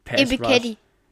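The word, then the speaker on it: Ebiketie: Stelle Arnold Epikete, hoffentlich habe ich das richtig ausgesprochen. – Epikete. – Oh, sehr gut.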